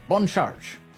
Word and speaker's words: Bon Charge: Bon Charge. That's the way you say it. – little French pronunciation.